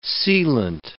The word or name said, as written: sealant